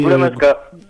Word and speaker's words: Goulamaska: Goulamaska, je crois, on dit, hein, malgré que ça s'écrit. – Goulamaska.